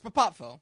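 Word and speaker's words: Popowo: What's the correct pronunciation for the town? Is it Papapvo? – Yeah, it's Papapvo.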